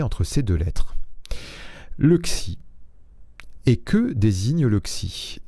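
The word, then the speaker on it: xi (ξ): Cette lettre, elle se prononce xi, se prononce comme le son d'un serpent, x.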